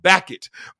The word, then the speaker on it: Bacot: Armando Bacot. Back it.